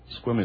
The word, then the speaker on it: Sequim: And it can be gray everywhere else, and you can go to Squim, and you find the blue hole. Squim is spelled S-E-Q-U-I-M.